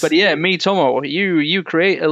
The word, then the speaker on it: Miitomo: And it's Miitomo.